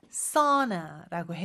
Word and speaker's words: sauna: 그런데 발음은 sauna라고 해요.